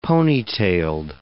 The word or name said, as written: ponytailed